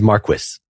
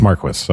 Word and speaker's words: Marquis: Just an FYI, I specifically pulled up a video just so I could see how to pronounce his name, and it is Marquis. – It's Marquis. Okay.